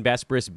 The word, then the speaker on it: Besbris: I am Dan Bespris. He's Brandon Marcus. Again, at BD Marcus, I am at Dan Bespris, B-E-S-B-R-I-S is how you spell that last name.